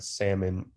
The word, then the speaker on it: salmon: Unfortunately, even though the spelling has changed, the French-influenced pronunciation without an L remained. Hence, the L in the English word salmon is silent.